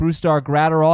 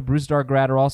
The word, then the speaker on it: Brusdar Graterol: Boston's getting starting pitching prospect Brustar Graterol. I believe that's how we pronounce it. Haven't been able to get confirmation on that from Minnesota, Brustar Graterol.